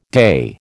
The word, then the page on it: day: American English